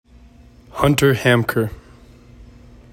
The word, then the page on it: Hunter Haemker: HUNT er HAM ker